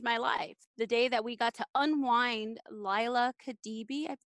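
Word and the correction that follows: Liya Kebede: Leah Khadibi, is it? – It's Leah Khabede. – Khabede.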